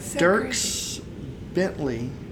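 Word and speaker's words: Dierks Bentley: Dirks Bentley, I think I'm saying that right. – Yeah.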